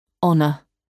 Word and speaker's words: honor: Le H est muet, c'est-à-dire qu'il ne se prononce pas. – Hour. Air. Honest. Honor.